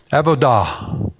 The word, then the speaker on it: avodah: So I've spent all week living in Hebrew words, and I've got a Hebrew word for you avodah.